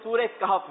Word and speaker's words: کہف: آپ نے کہا سورہ کہف، جبکہ صحیح جواب تھا سورہ کہف. آپ نے جو جواب دیا وہ درست، مان لیتے ہیں، 2 نمبر دیے جاتے ہیں. صحیح تلفظ جو ہے وہ سورہ کہف ہے.